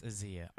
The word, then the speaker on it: Ayza: I think it's called Azia. – It's like, what is Azia? How does it spell it? A-Y-Z-A. – It's Azia.